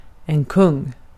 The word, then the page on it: kung: /kɵŋ/